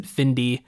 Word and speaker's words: Findie: So I guess you would pronounce it Findy, okay. Spelled F-I-N-D-I-E.